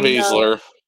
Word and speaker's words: Baszler: Shayna Baszler.